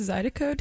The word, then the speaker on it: Zydeco: Is it pronounced Zydeco dance? Mm-hmm. – Yes.